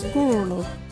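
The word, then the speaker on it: gurulhu: Our local Wadjuri word is Gooroloo.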